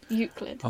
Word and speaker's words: Euclid: Well, I used to pronounce it as Euclid, but it's not Euclid. – Can you do two C's? – No, there's one C. Euclid. – Okay. – Spelled E-U-C-L-I-D.